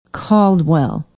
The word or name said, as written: caldwell